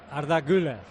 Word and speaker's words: Arda Güler: ¿Lo digo bien, Melchor? Arda Güler. – Arda Güler. – Perfecto.